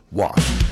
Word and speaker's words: Wolf: You know, and I keep wanting to pronounce it with the German W, but it's Jakarta Wolf from Minden.